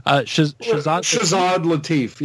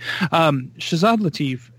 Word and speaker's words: Shahzad Latif: Shahzad Latif, yes. – Oh, did it. Yay, go me. Shahzad Latif, fantastic.